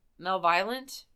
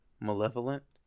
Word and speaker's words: malevolent: I think she's in Malviolent. – Malevolent. – Yep, that's the one. Yep.